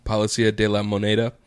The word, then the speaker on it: Palacio de la Moneda: Palacio de la Moneda.